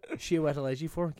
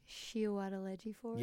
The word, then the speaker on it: Chiwetel Ejiofor: No, oddly enough, I was on his Wikipedia page 2 days ago and I looked at the pronunciation of his name. I don't ask me why I do these things. It was very boring work. Chiwetel Ejiofor, give it a go. – Chiwetel Ejiofor? – Yes, first go, straight at the bat.